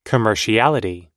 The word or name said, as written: commerciality